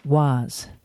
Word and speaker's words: Waze: This week's app is called WAZ, and that is spelled WAZE.